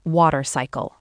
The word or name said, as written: water cycle